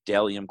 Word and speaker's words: Bdelliom: It's Isaac Thomas at Delium Clothing. Again, that's Delium, spelled B-D-E-L-L-I-O-M, Delium, but the B is silent.